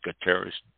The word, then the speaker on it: Guterres: In August, the United Nations issued a report that the Secretary General Antonio Guterres, I believe it's pronounced Guterres, said that a code red for humanity is happening regarding our current state and future of the planet because of global warming.